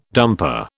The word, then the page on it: dumper: British English